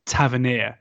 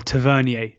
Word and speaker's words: Tavernier: Let's start with the most likely of the five, Marcus Tavernier, or Tavernier, depending on how you like to say his name.